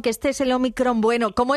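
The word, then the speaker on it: ómicron: Porque al principio, bueno, sí que es cierto que es Omicron. Uh-huh. Se pronuncia Omicron con a palabra esdrújula.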